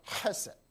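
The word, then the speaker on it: Hesed (חסד): 希伯来文它发音可能比较难，中国人发音比较难，它叫 Hesed。 Hesed，大家说 Hesed，这个是神特别的爱。在旧约里面说到神的爱，当他说到神与人立约的爱的时候，他就用这个字 Hesed，希伯来文。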